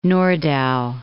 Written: Nordau

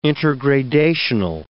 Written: intergradational

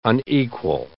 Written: unequal

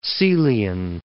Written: Caelian

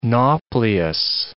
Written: nauplius

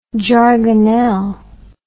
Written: jargonelle

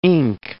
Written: ink

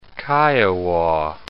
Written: Kiowa

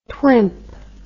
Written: twimp

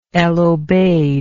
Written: El Obeid

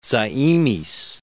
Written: Zaimis

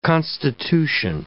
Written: constitution

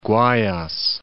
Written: Guayas

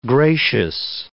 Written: gracious

